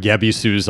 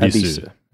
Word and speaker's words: Ebisu: But yeah, even when it's spelled that way with the old katakana, with, or in English with the Y at the beginning, it's still pronounced Ebisu. Ebisu, right? Okay.